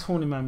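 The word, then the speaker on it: Horniman: So you've got God's Own Junkyard left, the National Gallery, I think, and the Horny Man Museum. Am I saying it right? Horny Man.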